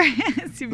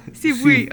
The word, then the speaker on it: CIVUI: I'm not sure. – CIVUI. But yeah, in Portuguese, it's uh-huh. CIVUI. – Sibui, okay. Sibui.